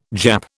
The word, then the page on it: Jap: British English